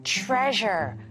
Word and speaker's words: treasure: Treasure, 보물이에요. 그래서 이 S를 좀 주의하세요. S, S 발음이 아니라 좀 Z, treasure. 이런 소리가 나죠.